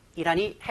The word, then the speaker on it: Iran: 이란이 핵폭탄을 손에 넣는 것을 막기 위한 취지로 이루어졌다는 것을 알 수 있겠습니다. 뭐 이란이라고 부르는 사람도 있고요. Iran이라고 발음하는 사람도 있는데요. 그냥 알아들으시면 되겠고 본인은 편한 걸로 쓰셔도 괜찮습니다.